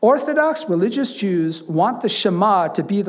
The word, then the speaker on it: Shema: Because the first word here in Hebrew is Shema. That's how you pronounce it.